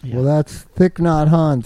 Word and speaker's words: Thich Nhat Hanh: It's Thich Nhat Hanh.